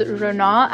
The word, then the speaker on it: Rona: My name is Rana Akbari. I pronounce it that way, but I also pronounce it Rona Akbari depending on who I'm talking to.